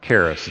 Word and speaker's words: charis: Charis. It's spelled C-H-A-R-I-S.